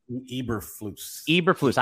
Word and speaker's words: Eberflus: Eberflus.